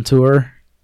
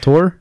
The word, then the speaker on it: Tor: Yeah, go on tour. Tor. How do you pronounce it? Tor.